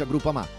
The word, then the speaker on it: Groupama: Groupama, si pronuncia Groupama, di Paolo Felicetti.